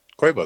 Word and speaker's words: Quavo: Quavo is how you pronounce it, or how he pronounces it.